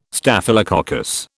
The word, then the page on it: Staphylococcus: British English